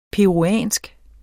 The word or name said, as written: peruviansk